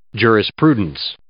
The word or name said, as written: jurisprudence